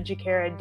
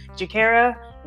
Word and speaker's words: Juakerra: So if you can find me on Instagram at Juakerra Davis, and because people often ask me, how do you spell your name? Davis, really easy. D-A-V-I-S. Juakerra, and spell it slow. J-U-A-K-E-R-R-A.